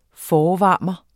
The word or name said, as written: forvarmer